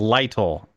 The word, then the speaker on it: Lytle: You called him Liddell. It's Lytle. L-Y-T-L-E.